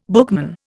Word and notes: bookman: Indian

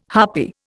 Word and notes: harpy: Indian

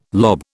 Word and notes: lob: British English

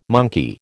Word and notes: monkey: American English

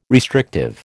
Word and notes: restrictive: American English

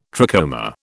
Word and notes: trachoma: British English

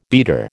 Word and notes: beater: American English

